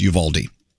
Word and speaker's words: Uvalde: and how to help Uvalde. – Wow. – Or as we say in Texas, Uvalde.